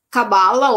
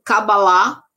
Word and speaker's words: Cabalá: E existe uma vertente, então, chamada Cabala ou Cabalá, né, como se fala em hebraico,